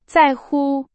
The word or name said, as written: 在乎